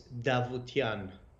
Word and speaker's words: Davoutian: So my name is it's pronounced D, the first two letters. So my name is Davoutian.